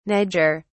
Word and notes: niger: British English

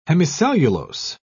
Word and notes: hemicellulose: British English

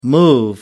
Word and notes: move in: American English

